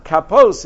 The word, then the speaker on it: kapos: Even though we pronounce it kapos in plural, it's written without the vav as if it said kapas tamarim, singular.